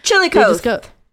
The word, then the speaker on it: Chillicothe: Construction workers in Chillicothe, Ohio, I think is how you say it. – Chillicothe. – Yes.